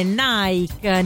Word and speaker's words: Nike: In realtà stavamo parlando, siamo partiti dal nome Nike, Nike, in pronunciato all'inglese, non alla greca come dovrebbe essere.